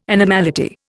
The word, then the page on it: animality: Indian